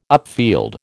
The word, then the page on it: upfield: American English